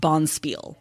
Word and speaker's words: bonspiel: And then Roland keeps saying bond spiel. However, I Googled it because, of course, and a bond spiel is quite simply just a curling tournament. – Okay. – And it is pronounced Bonspiel.